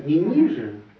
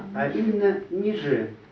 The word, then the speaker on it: ниже: Вот обратите внимание, ударение ниже. – Да, ниже сотворил. – Да, не ниже. – А ниже. – Да, а именно ниже.